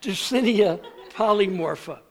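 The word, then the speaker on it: Dreissena polymorpha: There is this thing called Dracaena mollifera. Are you really impressed? You shouldn't be. I mispronounced it. It's Dracaena. Dracaena polymorpha.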